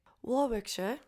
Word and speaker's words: Warwickshire: And she was discovered back in 2003 in Warwickshire, England. I have no idea if I said that right. Warwickshire.